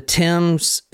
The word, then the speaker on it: Thames: The Thames in London. – Mm-hmm. No, that's At, correct. – You pronounce it correct.